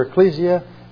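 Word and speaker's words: ekklesia: How do you pronounce that word? – Ecclesia. – Ecclesia.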